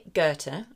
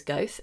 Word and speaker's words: Goethe: So when we think about this, and that's by Goethe, I've just learned how to pronounce his name, definitely have to admit that I thought it was Goethe this whole time, please forgive me.